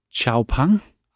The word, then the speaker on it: Xpeng: Og Xpeng udtales Xiaopang.